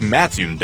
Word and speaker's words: Mattoon: Is it Mattoon or Mattoon? – It's Mattoon, dummy.